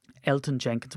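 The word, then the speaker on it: Elgton Jenkins: Elton Jenkins with the silent G, nice.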